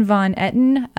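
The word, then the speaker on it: Van Etten: Uh, before you heard that song by Drugdealer, um, there was a song called uh, "We Are Fine" by Sharon Von Eaton or Etten. I'm not pronouncing that correctly, Sharon Von Etten.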